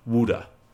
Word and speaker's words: wude: to take to achieve wude, w u d e. I made sure I said it right.